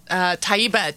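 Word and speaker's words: Tayyiba: Taiba, T-A-Y-Y-I-B, like boy, A. And Hanif is H-A-N-E-E-F hyphen Park, P-A-R-K.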